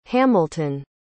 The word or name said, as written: hamilton